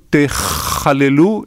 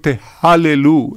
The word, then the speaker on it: תחללו: למשל, כתוב באיזשהו מקום, ולא תחללו את שם קודשי. אז הם יגידו, ולא תהללו את שם קודשי.